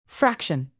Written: fraction

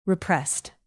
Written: repressed

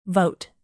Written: vote

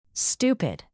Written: stupid

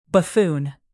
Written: buffoon

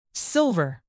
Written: silver

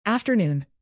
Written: afternoon

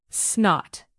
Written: snot

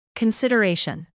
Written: consideration

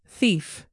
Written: thief

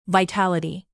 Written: vitality

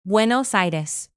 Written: buenos aires

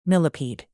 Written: millipede

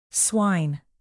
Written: swine

